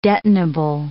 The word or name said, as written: detonable